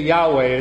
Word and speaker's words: Yahweh: Okay, Yahweh is the way scholars pronounce it today.